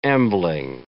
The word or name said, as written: ambling